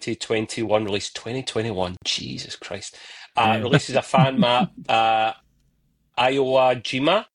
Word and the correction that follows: Iwo Jima: Iwo Jima.